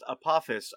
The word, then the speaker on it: Apophis: Apophis showed up and talked to, how is it actually pronounced? We've been pronouncing it wrong this whole time. – It's actually pronounced Apophis. Apophis is some type of monarch butterfly. There's no S, it's just a PH.